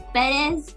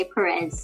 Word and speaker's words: Perez: Perez. So it's like, it's Perez, but a lot of people would just say Perez, so Perez.